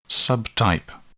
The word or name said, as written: subtype